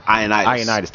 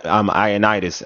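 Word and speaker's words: Ioannidis: Ionitis. – Thank you. Sorry, Matt. Ionitis.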